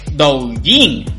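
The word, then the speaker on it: Douyin (抖音): como Douyin. Así se pronuncia en chino por si no lo sabías, lo sabes ahora.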